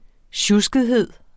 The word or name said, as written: sjuskethed